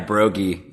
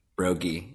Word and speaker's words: Brogy: Yeah, Brogy. Yeah, it's a hard G, Brogy. Yeah. I get a lot of Brogies, but it's Brogy.